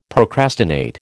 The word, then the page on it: procrastinate: American English